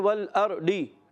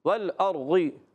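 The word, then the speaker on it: والأرض: بعض پڑھتے ہیں والارضی ڈال یہ بھی غلط نہیں پڑھنا والارضی دال یہ بھی نہیں پڑھنا اس کا اپنا تلفظ ہے اور بعض بچے غین جیسی آواز پہ بھی پڑھتے ہیں والارضی والارضی غی نہیں کرنا والارضی کیا پڑھیں گے والارضی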